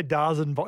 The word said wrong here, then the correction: DAZN: It's the zone. – Well, why is it spelt D-A-Z-N? – That's how it's spelt.